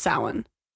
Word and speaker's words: Samhain: Which is spelt Samhain. Which I hate that it's spelt Samhain, but pronounced Samhain.